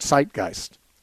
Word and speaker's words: Zeitgeist: If you go online and look up my name, Steve Gregg, with the word Zeitgeist, which is a German word, it's spelled Z-E-I-T, Zeit, and the same one word, G-E-I-S-T, Geist, means spirit.